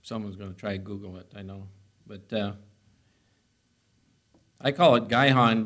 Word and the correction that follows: Gihon: but actually it's pronounced Gihon, G-I-H-O-N, Gihon.